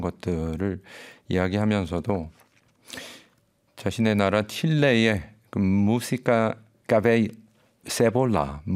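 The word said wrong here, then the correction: cebolla: 무시카 세볼라, 세보야군요. 발음이 세보야 같은 이런 신파 발라드 그리고 엔카.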